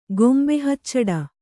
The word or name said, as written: ಗೊಂಬೆಹಚ್ಚಡ